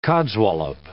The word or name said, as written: codswallop